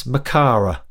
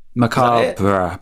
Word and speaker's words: Macabre: Dance Macara. – Macabre.